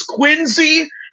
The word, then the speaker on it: Quincy: This is before I came to Quincy, which, by the way, I'm pronouncing it correctly. Please give me props to that. It's Quincy.